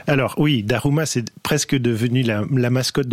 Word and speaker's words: Daruma: Ça s'appelle un Daruma. Je prononce bien ? – Tout à fait, tout à fait.